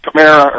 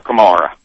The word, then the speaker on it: Kamara: Is Alvin's name pronounced Kamara or Kamara? – Both. – You say Kamara, I say Kamara. – Both.